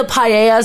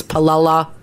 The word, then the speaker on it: paella: Like, maybe the paella sounds delicious, but you don't want to order it because you're not sure if it's palella or how to pronounce it.